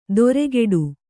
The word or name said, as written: ದೊರೆಗೆಡು